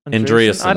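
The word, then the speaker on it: Andreessen: How do you say his last name? Andreasen? Is that it? Andreasen, that's it.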